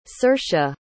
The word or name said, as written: saoirse